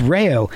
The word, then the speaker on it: Reux: Because they told me they were going out to gigs, and it's spelled like R-E-U-X or something like that, but it's pronounced Rayo. And people were getting it wrong even down there. And then, so they just spelt it out R-A-Y-O to avoid confusion.